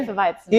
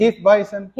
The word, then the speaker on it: Hefeweizen: Yo estoy con una Hefeweizen, ¿lo dije bien? Hefeweizen. – Hefeweizen. Hefeweizen.